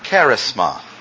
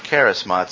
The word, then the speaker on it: charisma: Now, there are varieties of gifts, and you will recognize the word that is used there because it is the word charisma. Charisma, it's a different word. Accent on the first syllable. Charisma.